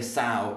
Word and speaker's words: sow: You pronounce sow like an A. Yeah, it's sort of like the word wow. Like, wow, that's amazing. We say sow in the same way. So sow, sow.